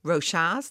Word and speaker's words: Rochas: including Chloé Rochas, that's how you pronounce it, I've just checked with him, and Christian Dior.